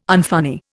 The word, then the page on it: unfunny: Indian